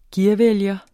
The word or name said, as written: gearvælger